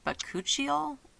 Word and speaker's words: Bakuchiol: I don't even know how to say it, but it's spelled B-A-K-U-C-H-I-O-L. Mm. Bacuchiol? – Yeah, I don't know. Knows? That does not sound right.